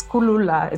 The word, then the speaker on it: Kulula: So Kulula in Zulu means easy, but Kulula in my language, Kamba, means to draw. So it's Kulula. It's pronounced a bit differently.